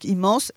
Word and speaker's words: Imans: Alors déjà, immense, ça s'écrit I-M-A-N-S, mais donc immense.